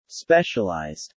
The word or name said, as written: specialized